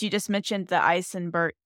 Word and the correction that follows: Eisenmenger: Eisen, how did we say it again? I looked it up. Eisenmenger. Eisenmenger yeah. syndrome.